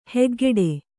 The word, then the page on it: ಹಕ್ಕುಗಟ್ಟು: hakkugaṭṭu